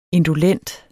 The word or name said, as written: indolent